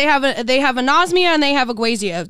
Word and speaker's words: ageusia: I don't know how you say this. It's like agusia. How it's spelled. Hold it's A-G-E-U. It tells me how to pronounce it, and I still don't know how to pronounce it. – Spell it for me. – A-G-E-U-S-I-A. – Agusia. – Yeah.